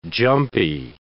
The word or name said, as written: jumpy